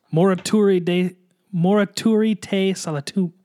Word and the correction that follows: Morituri te salutamus: I will say it right and we will edit all this out. Morituri te salutamus. – Thank you, for pronouncing that.